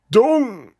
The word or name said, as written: manure